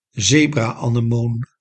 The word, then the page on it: zebra-anemoon: ze·bra·ane·moon